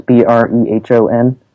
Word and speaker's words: Brehon: I don't know really how to pronounce it exactly, but I think it's called Breon Law. That's Brehon.